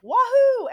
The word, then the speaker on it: Wahoo: And the clerk was like, Wahoo, as in Wahoo, I get to get out of here.